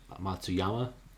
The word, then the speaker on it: Matsuyama: What about, uh, I think our the one takeaway from last weekend's tournament was Hideki Matsuyami Matsuyama. I'm really bad at pronouncing his name. I think say said different every time, but he's he putted really badly uh, last last weekend, and he's been playing well.